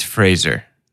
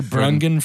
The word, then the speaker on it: Fraser: It's Fraser.